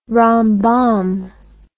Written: rambam

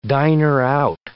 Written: diner-out

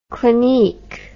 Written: clinique

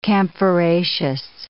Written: camphoraceous

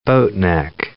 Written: boatneck

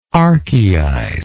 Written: archaizer

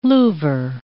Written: Louvre

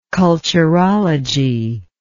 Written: culturology

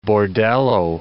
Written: bordello

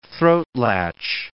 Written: throatlatch

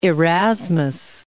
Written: Erasmus

